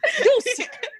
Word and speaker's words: Doos: It is spelled D-O-O-S. Deuce.